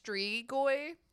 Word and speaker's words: Strigoi: It is apparently pronounced Strigoi. – Strigoi. Okay.